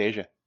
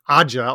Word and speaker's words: Aja: That's pronounced Asia? – It's pronounced Asia. – I've been reading that as Aja all the time and and not liking Steely Dan.